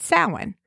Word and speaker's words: Samhain: and the UK celebrated Samhain. Now, that's spelled Samhain, or S-A-M-H-A-I-N, if you want to look it up.